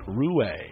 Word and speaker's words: Ruais: He spells it R-U-A-I-S, but pronounces it Rue.